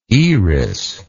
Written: Eris

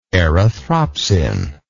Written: erythropsin

